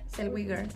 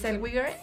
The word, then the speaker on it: Zellweger: ¿Cómo se pronuncia? ¿Zellweger? – Zellweger. – bueno, está bien. intentamos. no, sí, es Zellweger por Judy.